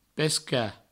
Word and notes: pescar: Provençal; pesca

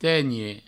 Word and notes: tenhe: tegne

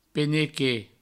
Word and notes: penequet: Provençal

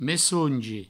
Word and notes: messongi: messoungi

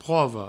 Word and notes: prova: provo; provençal

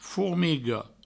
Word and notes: formiga: fourmigo

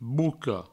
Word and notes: boca: bouco

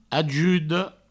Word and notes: ajuda: ajudo

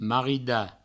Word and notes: maridar: marida

